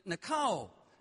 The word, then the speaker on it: nikao: Remember last week I told you the word overcome in Greek, it's pronounced nikao. Nikao, phonetically, is spelled N-I-K-A-O.